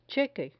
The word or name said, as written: cheque